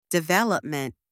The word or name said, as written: development